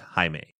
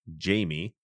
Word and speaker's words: Jaime: As I mentioned, you know, I've had a lot of years of Spanish courses, and that name in Spanish is pronounced Jaime. In English, you'd pronounce it Jamie.